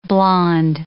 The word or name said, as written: blonde